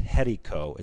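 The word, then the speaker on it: Jericó: It's spelled like Jericho, but it's pronounced Jerico.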